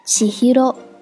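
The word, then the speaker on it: 千尋: 我们看到第二个七七，在《千与千寻》这部动画中，千寻的名字读作七 hiro，七 hiro。千寻的千在这里就读作七，我们再看看第五个，托托。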